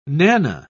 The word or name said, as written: nanna